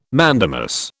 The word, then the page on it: mandamus: British English